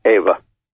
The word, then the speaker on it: Eva: Ava or Eva? What's the formal pronunciation? – In German, it's Ava.